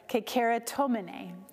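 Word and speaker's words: kekeratomene (κεχαριτωμένη): Now, this word that is translated in our Bibles as favored is the Greek word kekero, let me say that right, kekeratomene, kekeratomene.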